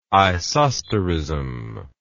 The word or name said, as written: isosterism